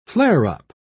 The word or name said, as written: flare-up